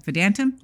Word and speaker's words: Vedantam: Shankar Vedantam had Vedantam is how he pronounces it on the podcast.